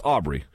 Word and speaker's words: Aubrey: It's Aubrey.